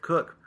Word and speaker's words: Cook: Nina Cock, Coach? – Cook. – Oh, like Derek M. Cook. Uh-huh. It's spelled the same way. Cook.